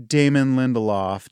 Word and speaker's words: Damon Lindelof: Well, knowing Damon Lindelof, did I pronounce his name you right? You did.